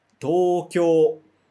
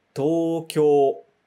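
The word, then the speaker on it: Tóquio (東京): Tá, mas isso era a pronúncia quando a gente fala aqui no Brasil, né? Vamos ver direitinho, lendo o hiragana na pronúncia japonesa direitinho, ok? Vamos lá. Tóquio.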